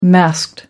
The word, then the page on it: masked: American English